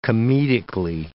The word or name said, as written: comedically